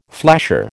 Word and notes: flasher: American English

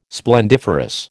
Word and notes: splendiferous: American English

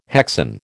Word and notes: hexane: American English

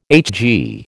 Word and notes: HG: American English

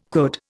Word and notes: could: Indian

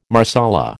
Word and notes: Marsala: American English

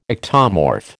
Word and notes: ectomorph: American English

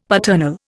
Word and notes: paternal: Indian

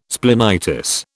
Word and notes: splenitis: British English

